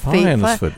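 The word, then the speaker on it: Fyansford: at Fiensport. I hope you say it like that. – Fiensford. Yeah.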